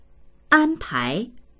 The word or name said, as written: an1pai2